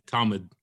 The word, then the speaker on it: Talmud: Yeah, Talmud.